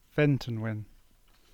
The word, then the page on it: Ventonwyn: English